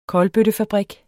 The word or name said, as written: kolbøttefabrik